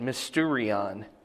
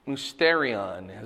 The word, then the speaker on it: mysterion: Okay, so mysterion is the way it's pronounced here.